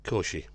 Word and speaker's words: Cauchy: Now, we think it's by Augustin Louis Cauchy. – Cauchy. Yeah? It's Cauchy, yeah.